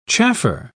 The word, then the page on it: chaffer: British English